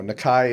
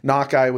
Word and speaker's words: Nakai: So I never get too picky on the pronunciation. I mean, you know, Nakai is more of a Polynesian Japanese pronunciation, but it doesn't bother me. Nakai would be the correct Navajo pronunciation.